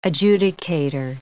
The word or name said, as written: adjudicator